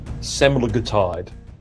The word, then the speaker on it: semaglutide: The active ingredient in those weight loss drugs is something, and I've got to pronounce this correctly, I think it is semaglutide, the S-E-M-A-G-U-L-T-I-D-E.